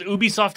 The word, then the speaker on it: Ubisoft: Is Ubisoft how you say it? – Yes. – Yeah, that's correct.